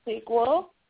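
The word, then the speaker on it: Sequiel: It's Sequel, like a movie Sequel? Is that how it's spelled? – Yes, it is. Yes, wow. – With an I. S-E-Q-U-I-E-L.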